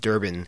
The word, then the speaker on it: Durban: sparkling beaches of his native Durban. Did I pronounce that correctly, Craig? – Yes, that's right.